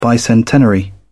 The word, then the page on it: bicentenary: British English